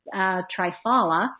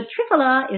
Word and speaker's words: triphala: But triphala, I've listened to some Indian doctors in doing the research. That's how they pronounce it. I've also heard it pronounced triphala. But triphala is what they say.